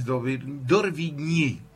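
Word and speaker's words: d'Orbigny: Un investigador y naturalista francés llamado Alcides d'Orvigny,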